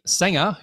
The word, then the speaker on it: Sangha: And today I am very, very grateful to have with me Purdeep Sangha, who's coming in all the way from Canada to discuss this very important topic.